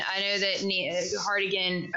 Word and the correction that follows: Harding: wait, why can I not get her name right? I'm combining names. You're trying to combine them. – I'm combining names. Harding, she like wore different outfits and stuff whenever you practice.